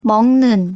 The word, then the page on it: 먹는: Korean